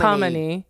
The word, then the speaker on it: Khamenei: Khamenei, the Ayatollah Khamenei. Khamenei. – Yeah. Khamenei. – Khamenei. – Yes. – Or Khomeini. – Khomeini. Khamenei. – Khomeini. Khamenei. It's like ebony ivory.